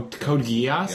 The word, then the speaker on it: Geass: I don't know how you pronounce it. The G-E-A-S-S. – G-E-A-S-S? – Yeah. – Oh, Code Geass? – Yeah.